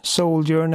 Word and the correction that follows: Sojourn: Eh, jag tror att det mm. uttalas Sojourn. – Ja. S-O-J-O-U-R-N. Mm, precis.